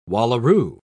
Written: wallaroo

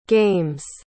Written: games

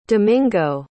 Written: domingo

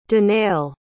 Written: danail

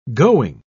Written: going

